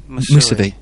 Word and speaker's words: Mousavi: Is it Mousavi? – Mousavi.